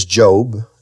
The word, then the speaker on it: Job: The story of Job, or as he's called by the uninitiated, Job, the book of Job, his name is Job, it's a long O, deals with the problem of man's trials and tribulations and troubles and his sufferings, his difficulties.